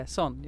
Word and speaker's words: SOHN: So it came from SOHN, which would be son, like father and son. And I've sort of reclaimed it for my own actual name. So it became SOHN, basically. – So, son, am I saying that right? – Yeah, SOHN, yeah. John, SOHN, it's all cool.